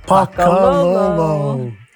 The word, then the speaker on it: pakalolo: Pakololo. – How do you spell it? – P-A-K-A-L-O-L-O.